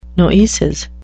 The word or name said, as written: noesis